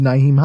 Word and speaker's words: Nyheim: Nyheim Hines. Holy shit, I said his name correctly on the first try.